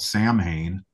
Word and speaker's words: Samhain: It's the third release from a band called Samhain. The holiday is pronounced Samhain, but the band we'll go with is called Samhain.